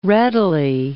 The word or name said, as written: readily